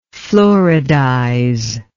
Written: fluoridize

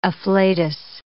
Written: afflatus

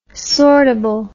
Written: sortable

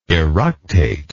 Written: eructative

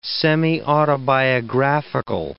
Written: semiautobiographical

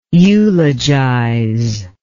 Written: eulogizer

